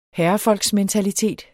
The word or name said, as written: herrefolksmentalitet